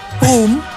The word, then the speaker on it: Poem: Hoe spreek je pruimen in de Buuls uit? – Poem. – Poem. Poem. Pruimenjam.